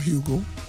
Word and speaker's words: Hugel: Let me give you this Hugel, or Hugo. Hugel, the G is silent, featuring French Montana and David Guetta, Aiden Martin.